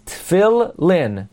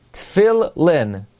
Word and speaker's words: Tefillin: Tefillin, the lamid has a dagesh. Some would say the way to pronounce it, therefore, is it's as if there are two lamids. Tefillin. Tefillin. Okay, so most accurately, lohaniach tefillin. It's like there's a double lamid.